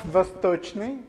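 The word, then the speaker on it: Vostochny (Восточный): Okay, it's pronounced Vostochny. Everybody remember word Vostok? Name of the Yuri Gagarin spaceship.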